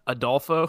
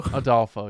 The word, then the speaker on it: Adolfo: What is, how's it pronounced there? – Adolfo. – Adolfo. Yeah.